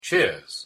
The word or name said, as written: cheers